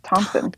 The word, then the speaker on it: Thomson: No, there's no P. It's Thomson. – Thomson. Am I saying it right? – Like Tom with not, but, like, not Tom.